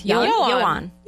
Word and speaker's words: Yoan: Y-O-A-N. I'm going to go with Yoan.